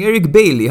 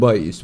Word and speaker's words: Bailly: I was saying Eric Bailey. How is this Eric Bailey guy? And it was like, Doug Bailly.